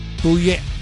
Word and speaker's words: Bouye: Bouye. – You got to say it like when you're in a crowd, you either say boo or yay.